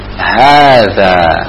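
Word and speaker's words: hadza (هذا): Perhatikan nulisnya ha, za, kemudian alif. Ya, dibacanya hadza. Ha panjang, za panjang. Hadza.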